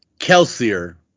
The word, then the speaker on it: Kelsier: This is going to be Kelsier, which is more than Kelsey. – Sure. – Okay. Kelsier.